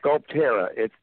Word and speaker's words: Sculpterra: Sculpterra. We combine two words, sculpture and terra, which is the land. So Sculpterra.